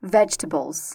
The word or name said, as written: vegetables